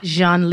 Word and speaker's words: Jean: Jean Lee. And Jean is spelled Jean, like J-E-A-N.